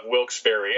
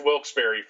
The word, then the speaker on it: Wilkes-Barre: Which is right above Wilkes-Barre, and it's pronounced Wilkes-Barre, folks. If you ever see it and think it's pronounced Wilkes Bar, just move on.